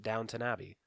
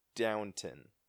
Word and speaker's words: Downton: I mean, that's how you should pronounce it, basically, because it's yeah, it's Downtown Abbey, but people pronounce it Downton Abbey. – Are you sure? Because Brielle has gotten mad at me and saying, no, it's Downton.